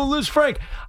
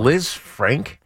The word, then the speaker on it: Lisfranc: Is Liz Frank a just type of injury? – Oh, in the toe? Yeah, because it's spelled L-I-S-F-R-A-N-C. So like it's French.